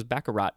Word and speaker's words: baccarat: But yeah, I thought it was baccarat, or baccarat, but it's no T.